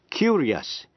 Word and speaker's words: curious: Phát âm làm hai âm tiết, những chữ không có âm C, âm T hay âm X đứng trước, I-O-U-S, và phát âm là ears. Thí dụ như chữ curious, C-U-R-I-O-U-S, là tò mò.